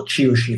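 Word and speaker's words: Qiushi: Okay, and if you looked at what she was saying and writing, specifically Xi, and we got a couple of excerpts from a magazine called Kyushu, or Chiushi if you're pronouncing it in proper Mandarin, which is the official CCP's magazine.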